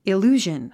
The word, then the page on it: illusion: il j úːʒən; イルージョン